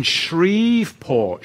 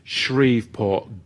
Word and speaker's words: Shreveport: Oh, you're in Shreveport. Is that how you pronounce it, Libby? Shreveport, good.